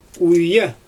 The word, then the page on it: wija: Xyzyl